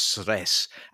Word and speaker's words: Cres: And actually, it's a road that rather oddly connects two islands, the island of Cres, which is pronounced Sres, and Mali Lošinj.